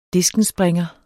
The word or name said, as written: diskenspringer